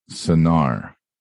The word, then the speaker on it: Sienare: If she was a girl who was seen as unfit to have children, she could be a Sinar. Now, this is spelled S-I-E-N-A-R-E. So we just talked about Anar. Now, a female is a Sai Sinar. So it sounds like sinner.